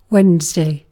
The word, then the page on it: keskiviikko: [ˈke̞skiˌʋiːkːo̞]; /ˈkes.kiˌviː.kːo/; /ˈkeskiviːkːo/